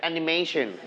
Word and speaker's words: animation: Together, animation. – Animation. – Animation. – Animation. – Animation. Very good. Okay, you stretch on the syllable may. Mation.